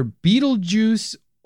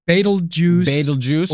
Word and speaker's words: Betelgeuse: You can say it's like either Betelgeuse or Betelgeuse. Or you could also do Betelgeuse. – Yeah. – Like you it's like how you pronounce the, you either do a long or a short E, and it's either an S or a Z sound at the end.